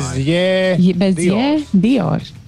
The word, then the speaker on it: Dior: Uh, jā, bet uh, pēc izrunas būtu jubu, burtam jābūt bez īburta. Diors, tā viņu izrunā. – Kristiāna Dior, Dior. taču viņa raksta. – Jā, bet izrunā Dior.